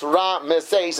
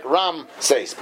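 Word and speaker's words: Ra-Meses (רעמסס): The reason why it's pronounced Ra-Meses is because the ayin has a shva, so it's Ra. Now, the reason this word often is pronounced wrong is because Ashkenazim don't pronounce an ayin. We do ayin like an aleph, which has no sound, so therefore it sounds like Ram-Meses.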